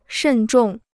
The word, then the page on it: 慎重: shèn zhòng